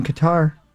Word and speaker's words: Qatar: Next Wednesday, it'll be 89 degrees in Qatar. – Good. – Oh, lucky. – Is that where you're going to be? – Yeah. – Is that how you say it? – Yeah. – Q-A-T-A-R.